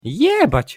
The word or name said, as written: jebac